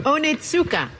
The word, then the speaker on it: Onitsuka: Onitsuka.